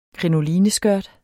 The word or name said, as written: krinoline